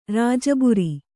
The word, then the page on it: ರಾಜಬುರಿ: rāja buri